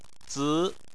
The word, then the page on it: zi: 2